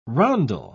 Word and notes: rondel: British English

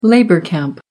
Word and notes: labour camp: American English

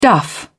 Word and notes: duff: British English

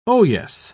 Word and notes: oyes: British English

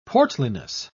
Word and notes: portliness: British English

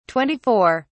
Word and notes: 24: British English

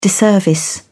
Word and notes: disservice: British English

